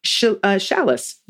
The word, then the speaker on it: Chalice: All right, this is from Chalice. Rhymes with Alice, and I know that because I read it.